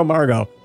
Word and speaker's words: Margot: You'd pronounce Margot Margot, but then you'd spell it with a T on the end, and I guess the T is silent.